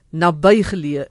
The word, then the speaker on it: naby geleë: Toe sê professor Ernst Kotse, ons taaladviseur, die korrekte uitspraak is naby geleë, net soos wat ons sê die nabye toekoms of dis 'n nabye familielid. Nou ja, daar het ons dit naby geleë is die korrekte uitspraak.